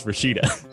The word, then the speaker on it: Rashida: Rashida? – Is that how it's pronounced? – Yeah. – Then her name is Rashida.